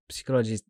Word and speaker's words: psychologist: So I've been told by a psychologist, I'm trying to pronounce that right. – One psychologist. Of those. – Yeah, because in English you don't pronounce the first P, which is like stupid, and after they blame French because you don't pronounce all the letters.